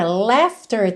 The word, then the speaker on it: laughter: Essa palavra laughter também temos de novo o GH com som de F. Olha, laughter, LAUGHTER.